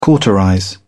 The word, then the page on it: cauterize: British English